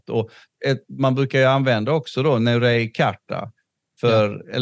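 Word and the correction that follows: Neturei Karta: eller hur uttalas det? – Neturei eh, Karta.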